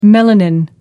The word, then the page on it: melanin: American English